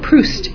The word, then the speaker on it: Proust: Marcel Proust, how do you say his name? Proust. Thank you.